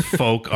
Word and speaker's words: Fouke: I looked up the Arkansas one because it's spelled F-O-U-K-E. Mm. And I'm like, I hope this is the fuck monster, but no. It's folk Arkansas, not fuck Arkansas.